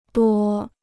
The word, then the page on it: 多: duō